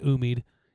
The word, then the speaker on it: Umeed: That's from Umeed. I believe it's how you pronounce it, Umeed. U-M-E-E-D.